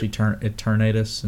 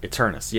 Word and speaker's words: Eternatus: Yes, where you catch Eternatus and... – Eternus, yeah, that's it.